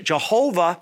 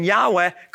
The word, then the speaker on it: Yahweh: You see, the way that the Gentiles began to pronounce it Jehovah rather than Yahweh comes from two sources. Number one, because the ancient Jewish scribes put the accent markings in the wrong places over the Hebrew consonants so that the Gentiles would mispronounce it.